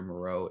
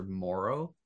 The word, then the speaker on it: Moreau: It is even more topical because he, I guess, came out and said that the way you pronounce his name is not Foster Moreau, it's Foster Moro. Like emphasis on the more.